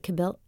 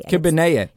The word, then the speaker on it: kibbeh: Is it kibbeh? How do I say this? It starts with a K. – Kibbeh. – Yeah, kibbeh.